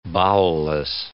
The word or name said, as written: bowelless